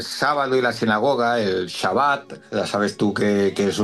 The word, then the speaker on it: Shabbat: Shabbat.